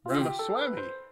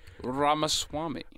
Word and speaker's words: Ramaswamy: Ramaswamy. – Vivek Ramaswamy. – Vivek. – But it's spelled Vivek. Vivek. – Ramaswamy.